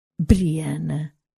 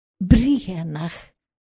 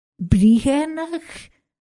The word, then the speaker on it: Brianna: Mas pronuncias de modo errado, Sassenach. Diz-se. E ele disse, com uma estranha cadência, acentuando a primeira sílaba e sussurrando apenas a segunda. Briennach. Briennach, repeti divertida. Ele assentiu, com os olhos cravados nas fotografias. Briennach. É um belo nome.